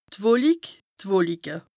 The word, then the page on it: d' Wolik, d' Wolike (pl): Bas-Rhin; Strasbourg